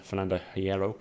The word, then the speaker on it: Fernando Hierro: Spain with a new manager, or temporary manager, Fernando Hierro.